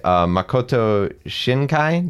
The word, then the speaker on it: Makoto Shinkai: It was directed by Makoto Shinkai.